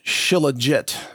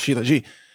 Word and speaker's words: Shilajit: We have both Zero Hedge and the Epoch Times now promoting a substance called Shilajit, which it looks like it might be pronounced Shilajit, but I think it's pronounced Shilajit, S-H-I-L-A-J-I-T. And the headline from the Epoch Times,